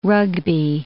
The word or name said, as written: rugby